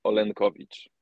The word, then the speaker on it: Olenkowicz: In Polish, it sounds Olenkowicz.